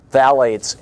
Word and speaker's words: phthalates: Phthalates, P-H-T-H-A-L-A-T-E-S, it's pronounced phthalates, in Jot brand blue three-ring binder.